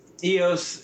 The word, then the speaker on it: Eos: Okay, so iOS 5. You're drinking an iOS 5. – No, it's Eos. It's spelled EOS.